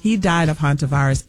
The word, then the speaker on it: Hantavirus: Hantavirus.